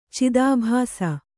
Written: ಚಿದಾಭಾಸ

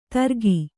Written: ತರ್ಗಿ

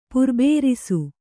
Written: ಪುರ್ಬೇರಿಸು

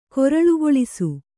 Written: ಕಾರಳೆಗೊಳಿಸು

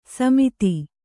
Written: ಸಮೆತ

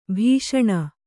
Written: ಭಾಷಣ

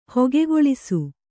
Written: ಹೊಗೆಗೊಳಿಸು

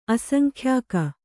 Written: ಅಸಂಖ್ಯಾಕ

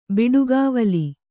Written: ಬಡಗಿವೊಲ

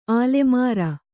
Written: ಆಲಮರು

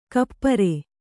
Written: ಕುಪರಿ

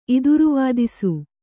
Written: ಇದಿರುವಾದಿಸು

